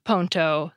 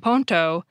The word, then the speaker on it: ponto: Receipt, which has a silent I and P in English, becomes recepto, spelled R-E-C-E-P-T-O. Bridge, which has a silent D and E in English, changes completely and becomes ponto, spelled P-O-N-T-O.